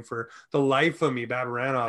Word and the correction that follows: Barabanov: Barabanov, Barabanov. There you go.